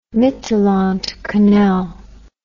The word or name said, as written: Mittelland Canal